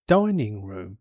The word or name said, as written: dining room